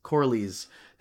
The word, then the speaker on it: Corlys: It's uh, Cor, Cor, I always say Corlaris. Oh, I say Corlys. Corlys. Thank you.